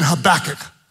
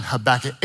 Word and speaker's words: Habakkuk: Say it again, Habakkuk. I'm gonna have you type it online, but I'm gonna tell you how to spell it. Type it online, Habakkuk, H-A-B-A-K-K-U-K, Habakkuk.